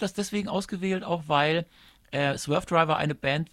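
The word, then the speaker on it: Swervedriver: Aber die Frage ist, wir haben jetzt hier, das wolltest du gerade schon sagen, eine Version von Swerved River. – Einigen wir uns auf Swerve Driver. – Swerve Driver, so kann man das auch aussprechen.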